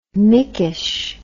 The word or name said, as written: mikes